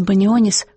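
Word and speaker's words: Banionis: Народный артист Донатас Банионис, по-литовски фамилия произносится Банионис, в советские годы был настолько популярным, что становился и героем фольклора, в смысле анекдотов.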